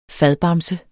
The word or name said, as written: FADBAMSE